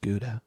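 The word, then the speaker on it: Gouda: It's pronounced Gudo.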